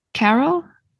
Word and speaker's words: Carell: Carell in the Big Short.